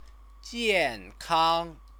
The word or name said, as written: jiankang